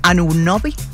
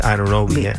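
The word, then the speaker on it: Anunoby: Anunobi, así se pronuncia. – OG Anunobi.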